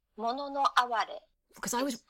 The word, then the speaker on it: mono no aware: yeah, I was really anglicizing it and calling it uh, mono no aware, which is uh, very very British. yes, yes, it's mono no aware and it sounds much more beautiful uh, in your language.